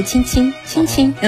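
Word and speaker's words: tchin-tchin: 不是，也是法语，但是它的发音就是亲亲亲亲。一样的。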